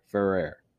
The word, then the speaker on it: Ferrer: Just think of For Air. Gasping For Air. Ferrer.